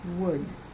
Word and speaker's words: would: Another spelling of uh is oul. The L is silent. Examples: would, could, should.